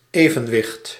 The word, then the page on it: evenwicht: /e.vǝn.ʋɪχt/